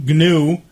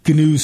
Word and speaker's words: GNU: It's the GNU, G-N-U, GNU, is in fact how they pronounce GNU in the GNU software world. – Okay. – I know it's new if it's the beastie. – The beast, yes. – But the GNU software pronounces it GNU.